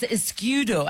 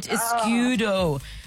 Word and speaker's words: Escudo: It's Escudo, I think is how you say it. Escudo.